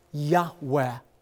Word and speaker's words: Yahweh: And his covenant name, Yod-Heh-Vav-Heh, oftentimes pronounced reverently, a very breathy Yahweh.